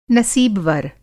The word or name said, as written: नसीबवर